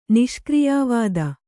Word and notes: ನಿಷ್ಕ್ರಿಯಾವಾದಿ: Kannada; niṣkriyā vādi

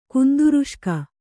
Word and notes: ಕುಂದುರುಷ್ಕ: Kannada

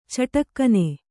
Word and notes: ಚಿಟುಕೆನ್: ciṭuken

